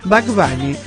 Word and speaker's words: Bad Bunny: estoy pronunciando mal, ¿no? – Bad Bunny.